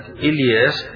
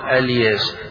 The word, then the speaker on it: إلياس: فهنا يقال في القراءة اللي فيها قراءة الجمهور وإن إلياس لمن المرسلين، قراءة ابن عامر بقول فيه وإن الياس وإن الياس، فإذا بدأ بكلمة إلياس الجمهور يبدأون إلياس، وإذا بدأ ابن عامر قال الياس.